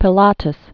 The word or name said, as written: Pilatus